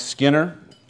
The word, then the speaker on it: Skinner: My name is Mike Skinner. You could pronounce it with a silent K, because I am a sinner, and I've gotten pretty good at that.